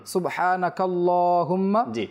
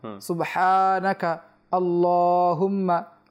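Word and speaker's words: সুবহানাকাল্লাহুম্মা (سبحانك اللهم): অনেকে কিন্তু এখানে এই সানা পড়তে গিয়ে কাল্লাহুম্মাও পড়ি আমরা, আবার আল্লাহুম্মাও পড়ি, কোন সমস্যা না, যদি কেউ মিলে পড়ে সুবহানাকাল্লাহুম্মা তাহলে সে কাফ এবং লামকে একসাথে মিলে নেবে, এখানে হামজাটা উচ্চারণ হবে না। অনেকে এরকম পড়ে যে সুবহানাকাল্লাহুম্মা, যখন সে একসাথে পড়বে তখন হামজাটাকে পড়বে না, সুবহানাকাল্লাহুম্মা। আর যদি সে হামজাটাকে পড়তে চায় তাহলে সে সুবহানাকা বলে থামবে, সুবহানাকা আল্লাহুম্মা ওয়াবিহামদিকা।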